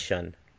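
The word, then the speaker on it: Xun: X-U-N. I think it's Shun.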